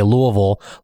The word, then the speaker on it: Louisville: I said, if you say it like you're saying the name Lou and the shape oval, and you say those together, that's basically how you say Louisville, like you're from Louisville.